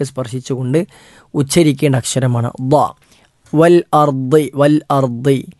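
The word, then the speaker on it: അർദ് (أرض): വൽ അർദി, വൽ അർദി എന്ന അർദ്, ഭൂമി എന്നർത്ഥമുള്ള അർദ്, അത് ദാദാണ്.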